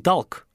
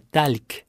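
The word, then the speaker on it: talc: И будьте внимательны, это «с» мягкое, не «ахк», «ахк», не «тальк», не «талк», а «тальк». И «л» мягкое, получается «тальк».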